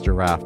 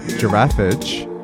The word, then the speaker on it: Giraffage: I'm sorry, I always say this, I always pronounce this group's name as Giraffe, but it's Giraffage, and the track is called Feels.